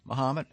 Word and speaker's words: Mahomet: Mahomet, as in Ali, at Yellow & Company.